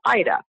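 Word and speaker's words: Ayda: Well, my name is spelled a little differently. It's A as an apple, Y as in yes, D as in dog, A as an apple. But it's pronounced Ida.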